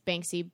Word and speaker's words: Banksy: And Banksy, right? That's how you say it. I always mix up the K and the S for some reason.